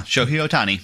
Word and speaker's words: Shohei Ohtani: Tell me, and I don't know how to pronounce this, Shohei Ohtani? – Yeah, Shohei Ohtani. Mm-hmm. You got it right.